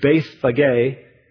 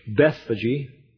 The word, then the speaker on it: Bethphage: In Greek, it's Bethphage. Some have pronounced it in English, Bethphage.